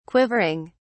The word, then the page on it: quivering: British English